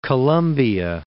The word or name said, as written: Colombia